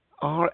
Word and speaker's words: Rasteau: It's, Krista Collection Rasiu. I can't pronounce that. I'll spell it again. Rasteau from France,